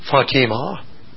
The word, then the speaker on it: Fatima: The Muslim pronunciation is Fatima.